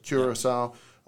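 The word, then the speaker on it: Curaçao: So you've dove in a couple tropical places, Puerto Rico, Curacao, did I say that right? – Curacao.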